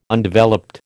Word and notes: Undeveloped: American English

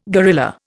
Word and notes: gorilla: Indian

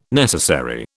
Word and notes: necessary: British English

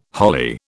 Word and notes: holly: British English

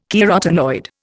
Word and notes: carotenoid: Indian

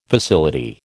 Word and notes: facility: American English

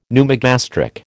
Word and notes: pneumogastric: American English

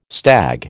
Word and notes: stag: American English

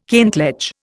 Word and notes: kentledge: Indian

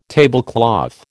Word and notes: tablecloth: American English

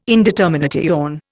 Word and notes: indetermination: Indian

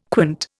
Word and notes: quant: Indian

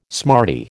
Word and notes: smarty: American English

